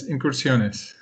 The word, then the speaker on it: Incursiones: So can you tell us about Incursiones? I don't know if I'm saying that correctly. – Yes, Incursiones.